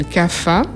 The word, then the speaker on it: Kapha: Et la troisième, qu'on appelle CAFA. Donc CAFA, je précise que ça s'écrit K-A-P-H-A.